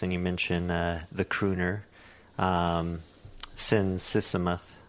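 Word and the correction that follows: Sisamouth: Um, it's Sin Sisamuth.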